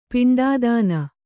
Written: ಪಿಂಡಾದಾನ

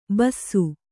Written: ಬೂಸಿ

